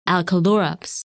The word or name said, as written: Alkalurops